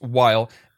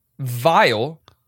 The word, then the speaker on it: Weil: W-E-I-L is Wile. Sounds like Wily, right? And I think they're going for that. But also, in German, that would be pronounced, the W would be pronounced with a V.